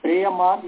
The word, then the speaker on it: प्रेयो मार्ग: जी प्रेय मार्ग जो हो गया वो जो है अविद्या है, श्रेय मार्ग जो है वो विद्या है, उसका शुद्ध उच्चारण है श्रेयो मार्ग, प्रेयो मार्ग, क्योंकि प्रेयस शब्द है श्रेयस शब्द है।